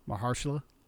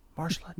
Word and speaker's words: Mahershala: Mahershala? Am I saying it right? – Mahershala. – Mahershala. Damn it.